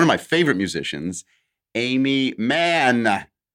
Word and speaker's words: Mann: Well, I yelled it because I'm excited, but I held the N because she has two N's in Mann. – You didn't hold the E's in Amy. – That's true. I should have. Amy Mann.